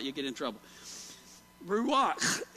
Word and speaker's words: ruach: It is ruach, ruach. And you kind of have to say it like you got a piece of popcorn stuck in the back of your mouth. You know that ruach.